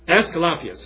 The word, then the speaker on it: Aesculapius: But most profound, and what the city was most known for, was its temple to Aesculapius is how it's pronounced.